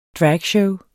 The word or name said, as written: dragshow